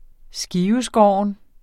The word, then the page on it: skiveskåren: [ ˈsgiːvəˌsgɒˀɒn ]